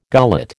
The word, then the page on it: gullet: American English